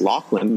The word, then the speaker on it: Loughlin: It's Loughlin, not Loughlin.